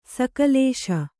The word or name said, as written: ಸಕಲೇಶ